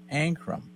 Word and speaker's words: Ankrum: They have added in the number 18 truck, Tyler Ankrum. Is that correct? Is that the right way to say his name? – Yeah, you did. It's perfect.